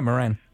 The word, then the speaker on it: Moran: No, it was when I drove over there on Monday from the, I'm probably pronouncing it wrong, Moran Junction. – There, yeah, Moran.